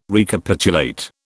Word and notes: Recapitulate: British English

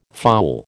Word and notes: foul: American English

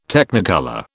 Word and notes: Technicolor: British English

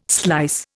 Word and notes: slice: Indian

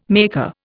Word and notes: maker: Indian

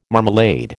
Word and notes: marmalade: American English

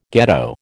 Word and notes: ghetto: American English

